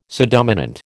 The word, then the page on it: subdominant: American English